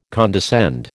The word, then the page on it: condescend: American English